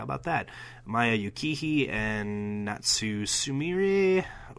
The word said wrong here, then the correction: Natsu Sumire: Ooh, butchered that one. Natsu Sumire. Sumire. Maya and Natsu against Sayori Anno and Jungle Kiona, who seemed like quite a big deal.